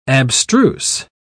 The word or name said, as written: abstruse